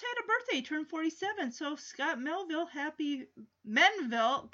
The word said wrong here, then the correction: Menville: I just mispronounced his name. Scott Menville.